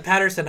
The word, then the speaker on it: Paterson: Or, as I read it, because there's just the one T, Paterson. – Oh, interesting. – But when people said Paterson, I was like, of course that's how you say it.